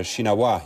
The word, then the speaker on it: Shinawatra: Het is Shinawa. Je spreekt de laatste 3 letters niet uit.